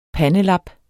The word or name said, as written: pandelap